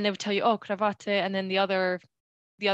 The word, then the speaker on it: Krawatte: And I remembered Krawatte. I'm sorry, my pronunciation is horrific. How do you say it, Chris? Krawatte. Krawatte.